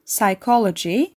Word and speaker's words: psychology: PN, PS ve PT ile başlayan kelimelerde ise sessiz bir P harfi vardır. Pneumonia, psychology, pterodactyl kelimelerinde olduğu gibi.